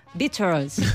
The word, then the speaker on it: Beatles: Anglosajona, y dice Beatles. ¿Cómo? Beatles. O sea, que en realidad Mikel lo ha pronunciado muy bien.